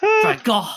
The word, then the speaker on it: Van Gogh: Yeah, it sounds more like cough in Dutch than it does to say Van Gogh.